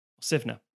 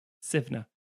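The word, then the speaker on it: Suibhne: Anyway, then you have Sivna. That is not how it's spelled on the page. It's S-U-I-B-H-N-E, Sivna. But I put a pronunciation guide for these guys because Irish.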